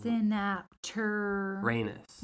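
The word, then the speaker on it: Synapturanus: Synapteranus. – Like Uranus, but right, it's synapt.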